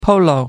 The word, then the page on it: polo: American English